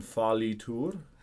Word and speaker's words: fallitur: And then on the next one, fallitur. – Fallitur. – Good, yeah. Just try not to pronounce the end R. Fallitur, like that. Just fallitur.